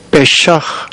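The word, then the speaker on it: 빼샥 (פשע): 이 허물이란 단어는 사진에 찾아보니까 빼샥, 빼샥 이렇게 발음이 되는데 이 히브리어는 구약 성경에 97번 나옵니다.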